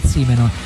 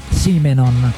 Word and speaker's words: Simonon: Paul Simonon, perché la pronuncia giusta è Paul Simonon. – Grande. – Chi Simonon, la pronuncia è Paul Simonon.